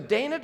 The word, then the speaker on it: Dinah: His daughter Dinah, I don't know if you say Dinah or Dina, technically in Hebrew you wouldn't say Dinah, but we're going to say Dinah because I'll forget how to say it.